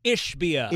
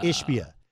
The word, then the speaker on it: Ishbia: Matt Ishbia. – Ishbia.